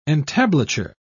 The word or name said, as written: entablature